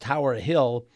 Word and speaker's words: TWRHLL: TWRHLL, pronounced Tower Hill, and named after her Long Island estate.